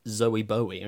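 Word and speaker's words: Zowie Bowie: And one of them is him saying, my son's name is pronounced Zoe Bowie.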